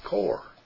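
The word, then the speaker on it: kor (קוּר): Well, when you look at the word web, it's 6980 in the Strong's, and in the Hebrew word broken into English is the word kor, K-O-O-R, kor, is how it's pronounced.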